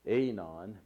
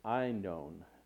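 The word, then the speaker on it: Aenon: Like I said, you know, sometimes whenever you look at these words, you know, we pronounce them in the English, you know, we would say Einon, but it's Einon. Actually, is how it's pronounced.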